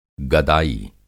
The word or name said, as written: गदाई